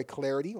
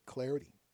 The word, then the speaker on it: Clardy: Hit me up on Twitter at Troy Clarity. Last name is spelled C-L-A-R-D-Y, at Troy Clarity.